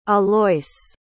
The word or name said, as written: alois